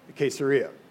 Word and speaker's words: Caesarea: Caesarea, which is the home of quesadillas, I think. No. I actually looked up the pronunciation. I'm like, okay, well, that helps, that it's like quesadilla.